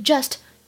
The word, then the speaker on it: just: 它的开头呢是一个辅音的 z，所以呢千万不要读成汉语拼音当中的鸡这个音啊，英语当中没有这个音，所以 just just。